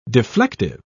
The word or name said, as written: deflective